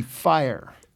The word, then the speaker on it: Fyre: Are we pronouncing it Fire? F-Y-R-E. – Yeah, it is. F-Y-R-E. Fire.